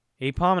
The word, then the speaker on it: Aipom: Aipom because it's an ape? Yay. – But that's not how you spell ape. – Yeah, but that's the pronunciation. Aipom and Aipom may be a combination of ape and palm.